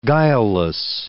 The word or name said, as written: guileless